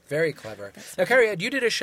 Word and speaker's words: Cariad: Did I say that correctly? – Cariad. It's a Welsh name.